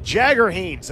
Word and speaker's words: Jagger Haynes: Let's pronounce that right. Jagger Haynes, as in Mick Jagger.